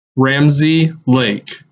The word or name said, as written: Ramsey Lake